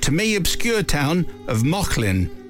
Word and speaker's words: Mauchline: But it's spelt M-A-U-C-H-L-I-N-E, which I, in my ignorance, had read as Munchline.